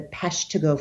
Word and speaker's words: Peshtigo: and that is the Peshtigo Fire. Now, I'm not sure if I'm pronouncing it in the Wisconsin way. – You're safe. Go ahead.